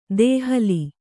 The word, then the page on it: ದೋಹಿಲ್: dōhil